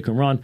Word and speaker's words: Qumran: that is how you say Qumran, Neoplatonism, which would, it's a later development, also around the time of Augustine, and then Manichaeism.